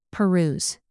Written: peruse

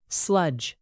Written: sludge

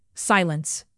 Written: silence

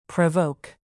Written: provoke

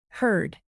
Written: herd

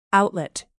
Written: outlet

